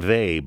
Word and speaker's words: Weil: The name Weil, by the way, is spelt W-E-I-L, in case you've been a reader but don't recognize the French pronunciation.